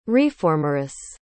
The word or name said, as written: reformeress